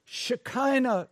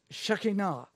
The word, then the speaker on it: Shekinah: That is the equivalent of God's Shekinah glory. Some pronounce it Shekinah. I hear both of them. But generally speaking, I hear Shekinah.